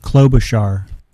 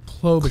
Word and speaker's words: Klobuchar: How do you pronounce it again? – Klobuchar. – Amy Klobuchar.